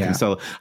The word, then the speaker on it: Cancelo: It was like a weird, like, it was like Kyle Walker played for a half and is it Cancelo? Cancelo, however you say his name? – Cancelo, I think it Cancelo. – Is. – Cancelo, yeah. – Not that we've ever been right about pronunciations on this podcast, but I'm going Cancelo.